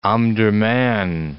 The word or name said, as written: Omdurman